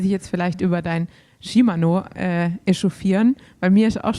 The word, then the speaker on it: Shimano: Jetzt habe ich ja aber eine japanische Teamkollegin gehabt und Shimano ist ja japanisch und dann habe ich sie mal gefragt, wie spricht man das eigentlich aus und sie hat bestätigt, es ist Shimano. Also ja. Es ist tatsächlich richtig.